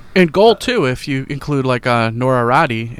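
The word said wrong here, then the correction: Räty: Yeah, Nora Ratu, yeah. It's a tricky one. It's spelled Ratti, R-A-T-Y, but because it's got the two little dots over that A, you're supposed to say Ratu.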